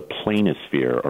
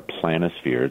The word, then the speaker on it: planisphere: It's called a planisphere or a planisphere. It can be pronounced either way.